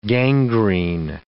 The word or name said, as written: gangrene